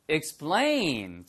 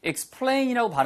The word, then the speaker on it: explain: 발음 하나만 주의하세요. 예, 이게 s 발음 다음에 ptk가 나오면 영어에서는 100% 예외가 없습니다. 100% 뿌, 뜨, 끄로 발음 납니다. 푸트크가 아니고. 그래서 explain이라고 발음하는 원어민은 한 명도 없고요. explain, 뿌, 뿌, 뿌처럼 발음하셔야 돼요.